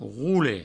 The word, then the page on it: rore: roure; Provençal